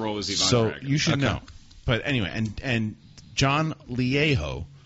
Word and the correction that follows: Lajoie: from The League. It's John Lajoie.